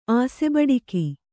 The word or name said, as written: ಆಸೆಬಡಿಕ